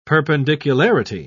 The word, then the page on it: perpendicularity: British English